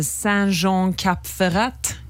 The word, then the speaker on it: Saint-Jean-Cap-Ferrat: Vi var i en by som heter Saint-Jean-Cap-Ferrat.